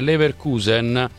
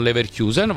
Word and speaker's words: Leverkusen: Roberto dalla Germania, scusami se eccepisco, ma ho vissuto molti anni a Leverkusen, si pronuncia semplicemente Leverkusen, non Leverkusen, va benissimo, allora accetto la tua lettura, Leverkusen, senza Kusen, senza la I.